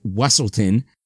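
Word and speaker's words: Weselton: And during the ball, the Duke of Weaselton, or as he pronounces it, Wesselton,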